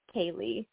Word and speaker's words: Kalli: My name is actually pronounced Kaylee. It's Kaylee. – Spelled funny. Yeah.